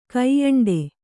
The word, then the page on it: ಕೋಯಂಡ: kōyaṇḍa